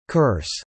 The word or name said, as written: curse